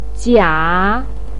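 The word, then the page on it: jia: tone 3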